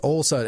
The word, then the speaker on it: Olson: It is or is not O-L-S-O-N? – It is Ole Son. It is Son. Son. Think Son. I'm a son of Ole. Not S-E-N.